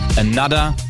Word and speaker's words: Anotr: Und das klingt natürlich im Englischen ausgesprochen bisschen geiler. Another heißt es.